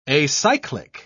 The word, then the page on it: acyclic: British English